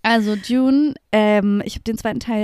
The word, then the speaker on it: Dune: Also ich war ja jetzt im Kino diese Woche und habe Dune geguckt, den zweiten Teil. Habe ich es richtig ausgesprochen? – Ja.